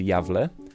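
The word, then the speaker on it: Gävle: Jedna rzecz tutaj, małą rzecz muszę wcisnąć, bo to jest, tu jest nazwa miasta, pisze się Gawle, ale czyta się Jawle.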